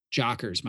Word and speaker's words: Jockers: So you just, it's spelled P I Q U E T E A dot com slash jockers, my last name, J O C K E R S.